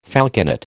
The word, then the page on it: falconet: American English